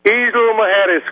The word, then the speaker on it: Isla Mujeres: So, am I saying it right? Isla Mujeres, is that right? Isla Mujeres? – Isla Mujeres, Greg.